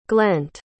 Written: glent

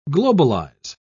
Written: globalize

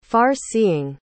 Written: far-seeing

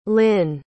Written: hlynn

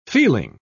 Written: feeling

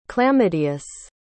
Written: clamedeus

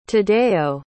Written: tadeo

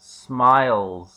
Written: smiles

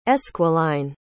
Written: esquiline

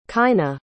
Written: kyna

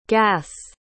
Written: gas's

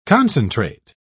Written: concentrate

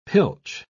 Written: pilch

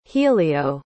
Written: helio

